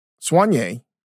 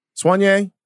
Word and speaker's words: soigné: Soigné gets thrown around a lot in certain kinds of restaurants, and it's S O I G N E. It happens to be one of the very few French words that I can pronounce properly.